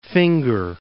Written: Finger Lakes

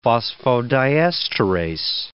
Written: phosphodiesterase

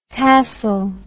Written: tassle

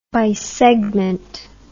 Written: bisegment

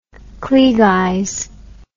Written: klieg eyes

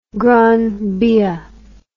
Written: Gran Via

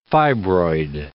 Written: fibroid

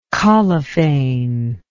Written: collophane

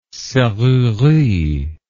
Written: serrurerie

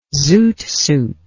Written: zoot suit

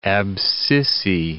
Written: abscissae

